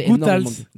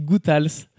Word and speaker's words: Guttals: D'ailleurs, ça se dit Guttals comme quelque chose qui a du goût.